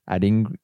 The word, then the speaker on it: Adingra: And speaking of someone who can make something happen in a game, Adoringa at Brighton. – Adringa? – Adringa. – No. How do you say his name? – Adingra? – Adingra.